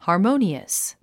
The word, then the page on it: harmonious: hɑːrmóuniəs; ハーモゥニアス